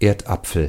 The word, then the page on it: pomme de terre: France (Paris); [pɔm də tɛʁ]